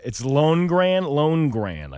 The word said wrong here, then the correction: Lonergan: I think, isn't it? – Lonergan? – Oh, maybe that's what it is.